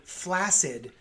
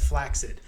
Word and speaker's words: flaccid: Kelsey Grammer was on an interview, and apparently flaccid, it should be pronounced flaccid.